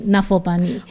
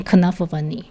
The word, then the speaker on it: Knuffle Bunny: 对，因为荷兰文这个这个这个名字，因为这本书很多人如果不知道作者的背景，或者没有听过作者自己念这本书，很可能照英文发音会念 Nuffle Bunny。哦，可对，可是其实 Mo Willems 他是念 Knuffle Bunny。哦，因为他是荷兰裔的人，那所以在第二集的时候，这个。